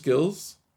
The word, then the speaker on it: Gilles: It's Gilles. Is it just Gilles? – Yeah. Yeah.